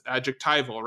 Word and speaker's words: adjectival: Is that how we pronounce it? You just taught me this word, so that's adjectival, right? – Mm-hmm.